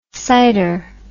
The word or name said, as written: sider